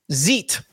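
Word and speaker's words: xeet: This is from X's Help Center on how to zeet. How would you pronounce that? Zeet? – Yeah, like Xerox. – Like a xylophone.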